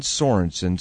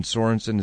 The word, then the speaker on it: Sorensen: All right, we're talking to John Sorensen. Sorensen is spelled S-O-R-E-N-S-E-N.